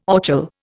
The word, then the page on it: orchil: Indian